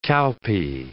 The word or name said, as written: cowpea